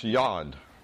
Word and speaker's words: yad: It's spelled Y-A-D and pronounced Yod.